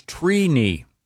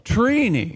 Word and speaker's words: Trini: The correct pronunciation is Trini. – Wow. – Trini. – Like T-R-E-E. – Like weenie. – Yeah, So is it phonetically T-R-E-E-K-N-I? – Yeah, phonetically it is T-R-E-E.